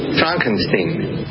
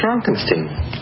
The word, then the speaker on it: Frankenstein: Frankenstein. – You're putting me on. – No, it's pronounced Frankenstein.